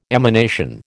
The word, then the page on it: emanation: American English